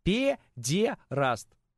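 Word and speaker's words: педераст: Пе-де-раст. Вот именно так надо произносить. Никак иначе. Не как Никита Сергеевич Хрущев. Ни в коем случае.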